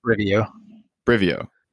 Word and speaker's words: Brivio: Brivio.